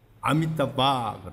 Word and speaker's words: Amitabha: 아미타부아 하는 것은 영어 스펠링으로 표기하면은 AMITABHA 아미타바 그래.